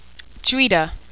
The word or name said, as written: jhuidah